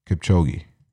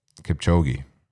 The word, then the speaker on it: Kipchoge: I think is Kiyoshi. I don't want to butcher his name. But that's insane. Kyoji? Kipchoge. Yep. Kipchoge.